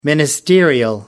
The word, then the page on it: ministerial: American English